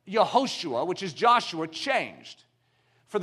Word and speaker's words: Yehoshua: But Joshua is actually pronounced Yehoshua, which means Yahweh saves.